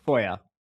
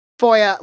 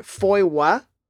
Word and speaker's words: FOIA: So it is FOIA Ball, F-O-I-A-B-A-L-L, but FOIA. – FOIA, like, rhymes with Goya, the yes. Black bean company or something. Okay, great. Yes. That's how I pronounce it as well. The other two ways I have heard people say it, I've heard FOIWA, with a sort of like W. I'm not here to judge.